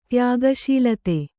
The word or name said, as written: ತ್ಯಾಗಶೀಲತೆ